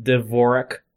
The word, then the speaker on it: Dvorak: I said the Aardvark. It's probably not how it's pronounced, though. – Yeah, like I think it's pronounced Dvorak.